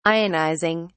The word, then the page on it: ionizing: British English